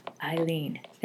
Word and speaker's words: Aline: Spelled A-L-I-N-E, but it's pronounced like Eileen.